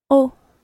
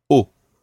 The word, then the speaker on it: eau: The word for water in French is eau. – Eau. – It's spelled E-A-U, eau.